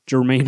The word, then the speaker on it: Jermaine: Rick Remender, Herme, uh, uh excuse me, Jermaine Opeña. I forgot to say his name.